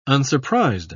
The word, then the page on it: unsurprised: British English